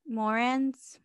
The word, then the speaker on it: Moran: So on February 14, 1929, four men dressed up as policemen, and they showed up to the mobster Bug Morgan's headquarters, or how do you pronounce that name? Moran's.